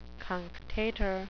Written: cunctator

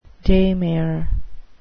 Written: daymare

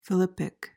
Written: philippic

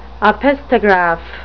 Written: opisthograph

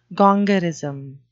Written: gongorism

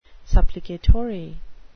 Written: supplicatory

